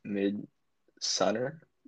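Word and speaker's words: Midsommar: And then the second recommendation is Midsummer, which was really weird, but I'd say it's worth a watch if you're interested. I was instructed to pronounce it Midsunner by someone. So this is me saying that.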